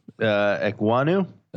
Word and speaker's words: Ekwonu: How do I pronounce that name again? – Ekwonu.